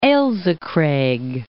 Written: Ailsa Craig

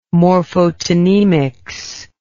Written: morphotonemics

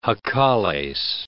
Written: jacales

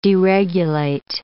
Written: deregulate

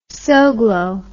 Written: Soglo

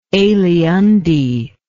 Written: aliunde

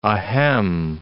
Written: ahem

